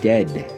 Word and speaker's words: Dehd: Preceding Launder, the band Dead, spelling their name D-E-H-D, so I think you'd pronounce it Dead.